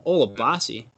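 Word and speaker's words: Olabisi: Before you insult me, you got to say it correctly. Olabosi.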